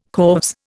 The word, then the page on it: corves: Indian